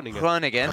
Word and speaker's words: Groningen: Groningen.